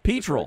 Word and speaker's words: petrel: Correct me if I'm mispronouncing it. The Stormy Petrels? – It's a Petrel. – Petrel. – It's actually a Petrel.